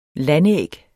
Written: landæg

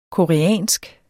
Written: koreansk

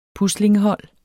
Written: puslingehold

